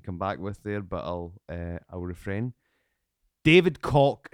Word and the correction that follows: Koch: and Charles Cock. Does that mean anything to you? – No. – I think that's how you pronounce their name. They own the Cock Industries. Or is it Coke? – Oh. – Is it Coke? – Yeah. Yeah, the Koch brothers.